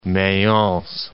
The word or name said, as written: Mayence